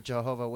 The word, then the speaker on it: Jehovah: He's from the Jehovah's Witness. Pronounce God's name correctly. It's Jehovah. Or if you're Hebrew, it's Yahweh.